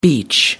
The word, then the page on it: beech: American English